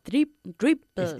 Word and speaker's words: dribbles: I love the way he dribbles, con una B más pronunciada. – Dribbles. – Y sin la R española.